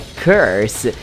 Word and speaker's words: curse: a pronúncia perfeita de maldição é curse.